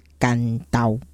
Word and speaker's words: 关渡: 那国语汉字是写关渡没有错，但是当地的古早名字的发音其实是干刀。